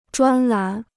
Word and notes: 专栏: zhuān lán

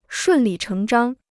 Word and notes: 顺理成章: shùn lǐ chéng zhāng

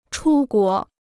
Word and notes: 出国: chū guó

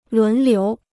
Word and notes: 轮流: lún liú

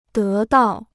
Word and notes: 得到: dé dào

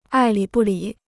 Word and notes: 爱理不理: ài lǐ bù lǐ

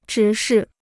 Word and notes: 直视: zhí shì

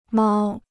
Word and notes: 猫: māo

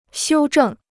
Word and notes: 修正: xiū zhèng

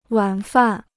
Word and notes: 晚饭: wǎn fàn

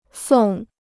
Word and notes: 送: sòng

